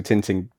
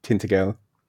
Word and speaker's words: Tintagel: Like I, I'm always amazed by like uh, Castle Tinting Tintagel.